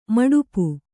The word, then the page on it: ಮಡಿಪು: maḍipu